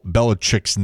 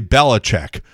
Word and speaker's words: Belichick: for all the sportscasters to mispronounce Bill Belichick's name, they all say Belichick, and it's not, it's Belichick.